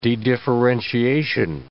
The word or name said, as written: dedifferentiation